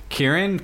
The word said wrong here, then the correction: Kyren: Kyren Williams. – Kyren.